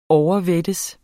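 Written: OVERVÆTTES

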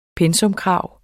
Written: PENSAKRAV

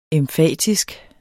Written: emfatisk

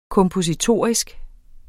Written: kompositorisk

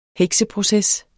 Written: hekseproces

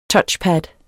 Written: touchpad

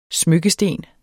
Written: smykkesten

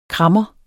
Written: krammer

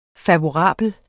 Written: FAVORABLE

